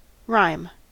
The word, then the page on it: rhyme: American English